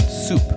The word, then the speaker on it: soupe: The English word soup comes from the French term soupe, spelled with an E, but it's pronounced soup. This word, meanwhile, has its origins in the vulgar Latin term supa, S-U-P-P-A, which means a bread soaked in broth.